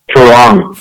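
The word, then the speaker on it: farang: I know they have, like, a term, Farouk, is it? How do you say it? – Farong.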